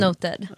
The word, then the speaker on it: NOTD: Men jag älskar er merch för då står det noted och under inom parentes, ja, pronounced, pronounced noted.